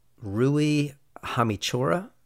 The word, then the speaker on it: Rui Hachimura: Actually, that NBA player, Rui Hachimura. – Yeah. – Is that how you say his name? – Yeah.